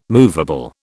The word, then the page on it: moveable: British English